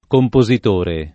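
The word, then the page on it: compositore: italiano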